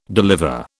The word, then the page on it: deliver: British English